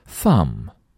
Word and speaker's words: thumb: Thumb, thumb, 注意最后的 b 不发音，是大拇指。